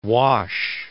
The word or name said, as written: Wash, The